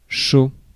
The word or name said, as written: hard